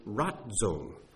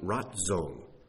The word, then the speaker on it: ratzon: But that word is almost identical to another word, ratzon, which is spelled the same way, except instead of the Zayin there, it's got a Zadi, ratzon.